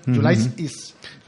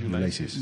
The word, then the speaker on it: Ulysses: Ulysses. He tenido que mirar cómo se pronuncia en inglés, ¿eh? Ulysses. – Muy bien, muy bien. – Igual que Hiram. Hiram. – Ulysses.